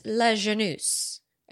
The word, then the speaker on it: Lajeunesse: And this comes from Google's former head of international relations, his name being Russ Lajeunesse, I believe is how you say his last name. Last name spelled L-A-J-E-U-N-E-S-S-E.